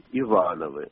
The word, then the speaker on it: Ивановы: Мама вспоминала, что бабушка всегда именно так ставила ударение на «а». Говорила «мы Ивановы».